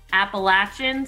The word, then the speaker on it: Appalachian: And you are Director of Public Safety and Chief of Police at Appalachian State University.